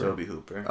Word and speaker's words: Tobe Hooper: Is it Tobe Hooper or Toby Hooper? – Toby Hooper. – Okay, that's really weird. T-O-B-E is how you spell it.